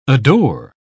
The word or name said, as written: adore